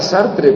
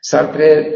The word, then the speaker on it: Sartre: Lo estoy pronunciando como se escribe, se diría Sartre, ¿no? Pero se pronuncia, se escribe Sartre. S-A-R-T-R-E, Sartre.